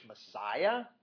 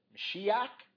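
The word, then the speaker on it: Mashiach (משיח): You pronounce it in English, Messiah, but the Hebrews would have said Mashiach.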